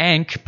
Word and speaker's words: Ankh: The Ankh, pronounced like dank without the D, is an ancient Egyptian hieroglyphic ideograph with the meaning life.